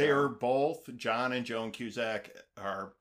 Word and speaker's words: Joan Cusack: Joan Cusack. Am I saying that right? – Yeah, Joan Cusack.